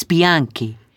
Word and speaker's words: Bianchi: It's Bianchi. You spell that B-I-A-N-C-H-I.